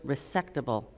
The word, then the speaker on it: resectable: resectable lung cancer.